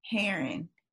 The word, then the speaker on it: Herron: How do you pronounce it? – Heron. – Heron. – I want to say it's like a bird. Is it a bird named a heron? – Girl, I don't know.